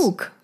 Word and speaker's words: Mook: Wie spricht man sie aus? Mook. Mook. Ja.